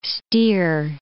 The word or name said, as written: steer